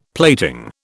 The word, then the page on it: plating: British English